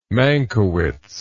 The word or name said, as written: mankiewicz